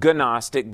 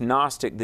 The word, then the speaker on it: Gnostic: One of the things I want to say, we hear the term kicked around, Gnostic, G-N-O. It's not Gnostic, but it's Gnostic. The G is silent. G-N-O-S-T-I-C.